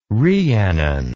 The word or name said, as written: rhiannon